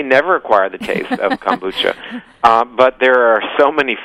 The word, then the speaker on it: kombucha: Some people, I know I've tried, is it kombucha? Is that how you say it? – Kombucha, yes.